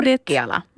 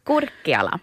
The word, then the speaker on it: Kurkiala: Mikael Kurkiala, tror jag. – Kurkiala. – Det är ett U där. – Mm. Då blir det är det där finska uttalet. – Ja. – Kurkiala.